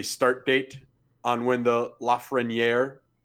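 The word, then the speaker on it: Lafrenière: It is Lafreniere. – Okay. – There you go. – Lafreniere.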